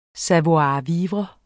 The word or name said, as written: savoir-vivre